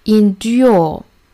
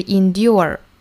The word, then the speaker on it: endure: 英音的话，我们是这样读的，endure，我们能够听出来是有 e 的发音的，endure。美音版的话呢是 endure 是没有 e 的，并且尾音要卷舌，endure a little pain。